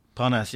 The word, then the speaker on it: Parnas: And by the way, I urge your listeners to listen to the Parnassus tape. Is that what it's called? – Parnas, yeah. – I've forgotten how to pronounce it.